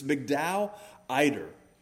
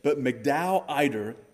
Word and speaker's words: Migdal Eder: It's the title of the message, but it's Migdal Eder is how it was given to me phonetically.